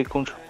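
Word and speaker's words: Khonshu: Kunshu, sí, Kunshu.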